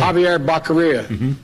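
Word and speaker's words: Xavier Becerra: Javier Becerra.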